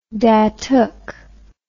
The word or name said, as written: datuk